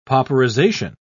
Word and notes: pauperization: British English

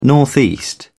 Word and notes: northeast: British English

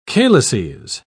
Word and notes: calices: British English

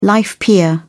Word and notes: life peer: British English

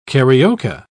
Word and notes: carioca: British English

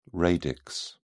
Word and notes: radix: British English

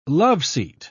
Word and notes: loveseat: British English

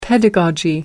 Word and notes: pedagogy: American English

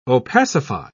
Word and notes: opacify: British English

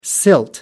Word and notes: silt: American English

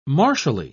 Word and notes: martially: British English

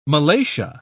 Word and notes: malacia: British English

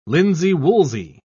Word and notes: linsey-woolsey: British English

